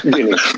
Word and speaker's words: Gini: Did, and did I mispronounce that? Is it Guinea or, or Guinea? Guinea.